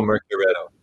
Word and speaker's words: Mercuretto: Uh, Mercuriato, did I say it right? – Mercuriato. – Mercuriato. Mercuriato. Mercuriato. Um, we're coming up to track one. Mercuriato. – you guys told me you had a contest of people uh, pronouncing that, so. – Yeah, it's it's it's the pitfall you fall into when you make up a word uh, that doesn't exist and people got to figure out how to say it. So yeah. Yeah. Time will come. Everybody will know Mercuretto.